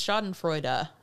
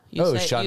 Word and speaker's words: Schadenfreude: Apparently we are mispronouncing it. It's Schadenfreude. – Schadenfreude. – You say the E at the end. So it's a German word.